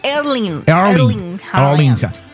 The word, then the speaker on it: Erling Haaland: Erling Haaland.